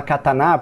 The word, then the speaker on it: katana: O japonês não tem sílaba tônica igual a gente tem. Então, a, a, depende de como a pessoa vai ouvir. Mas o pessoal costuma falar katana, porque se você falar as três sílabas tônicas, pra gente soa como se fosse sempre a última, né? – É, ah, então eles entendi.